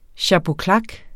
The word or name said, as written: chapeau claque